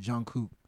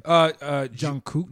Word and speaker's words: Jungkook: But some of the moves she's making, like putting her on a song with Jungkook. I don't know, is that how you say his name? Jungkook.